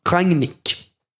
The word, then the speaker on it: Rangnick: Där det var, dels så var det en tysk då som rättade mig i hur man uttalar det. Mm. Så det är Ralf Ran Rangnick ska man uttala det.